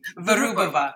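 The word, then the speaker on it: Vyrubova: There's also Alexander's friend, Anna Virubova. I hope you're going to correct my Russian pronunciation on the way through. – Virubova.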